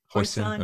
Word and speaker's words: hoisin: Am I saying that right? – Hoisin.